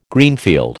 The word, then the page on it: Greenfield: American English